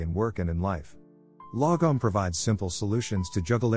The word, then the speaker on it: lagom: The Swedish concept of lagom, pronounced lagom, roughly translates to not too little, not too much, just right.